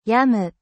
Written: igname

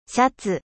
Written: chemise

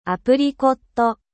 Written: abricot

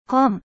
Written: livre

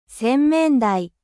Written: lavabo

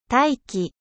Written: 大気

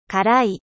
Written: epice

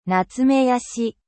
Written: datte

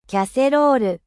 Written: cocotte

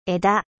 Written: 枝

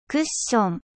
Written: coussin

